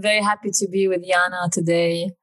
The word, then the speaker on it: Jana: It's Yana, yeah. It's the Slavic, I guess, pronunciation, because I'm Estonian. Uh-huh. And the J is, it's the same as like a Y in English.